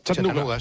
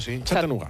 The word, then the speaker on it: Chattanooga: ¿Se pronuncia así en Chattanooga? – Chattanooga. Chattanooga.